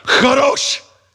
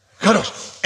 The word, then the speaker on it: Kadosh: If the person in front of you is not wiping stuff off the back of their hair, you didn't say it right. Kadosh. Kadosh.